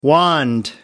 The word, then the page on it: wand: American English